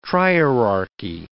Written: trierarchy

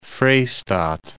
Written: Vrystaat

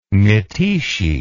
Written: mytishchi

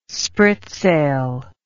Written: spritsail

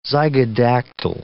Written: zygodactyl

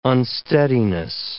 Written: unsteadiness

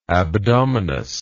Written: abdominous

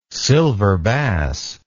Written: silverbass

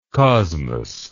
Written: kosmos

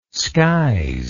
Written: skies

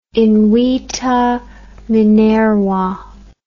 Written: invita Minerva

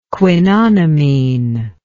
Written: quinonimine

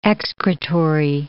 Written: excretory

